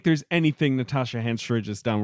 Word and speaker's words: Henstridge: Henstridge.